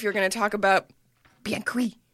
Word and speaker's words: bien cuit: The first word is B-I-E-N and the second word is C-U-I-T. And C-U-I-T. – Yeah. So it's like. – Bien cuit. – I know. It's bien cuit. But you have to say it in French if you want to say it right, which makes me uncomfortable.